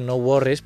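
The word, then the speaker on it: NxWorries: I anem a acabar amb un altre nom, no?, que és NX Worries, però que es pronuncia No Worries,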